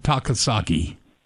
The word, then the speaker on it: Takasaki: find Ted Takasaki? – You betcha.